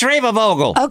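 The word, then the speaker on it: Schreibvogel: Now, I think you pronounce his name Schreibevogel. – It's Schreibevogel. – Okay, thank you.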